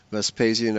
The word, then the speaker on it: Vespasian: I think his name is most often rendered in English as Vespasian. Vespasian, I think, is the usual pronunciation.